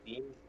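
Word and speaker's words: Digne: He pronounces it Dean. Lucas Dean is how he pronounces it.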